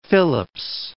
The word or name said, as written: Phillips